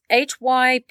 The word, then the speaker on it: hypnagogic: It's hypnagogic. – Okay. – Hypnagogic. hypnagogic. Yeah. It rhymes with logic, but it's not.